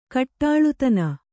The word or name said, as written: ಕಟ್ಟಾಳ್ತನ